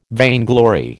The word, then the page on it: vainglory: American English